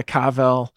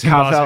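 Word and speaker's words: Carvel: She would just get a Cavel, which is how they say it in Boston. – Yes, Cavel, in Boston, Cavel ice cream.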